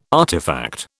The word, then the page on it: artefact: British English